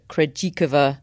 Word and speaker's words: Krejčíková: And I think we're going to come on to talk about Barbora Krejčíková. That is how I'm pronouncing her name on the basis of how she pronounces it on the WTA website.